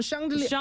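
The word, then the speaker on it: chandelier: 샹들리 어떻게 읽어요? – 그거 네. 맞아. 근데 불어로는 샹들리에. – 샹들리에. – 샹들리에. – 샹들리에. – 네. – 네. 영어로 어떻게 하죠? – 영어 뭐 미국 발음으로는 샹들리어.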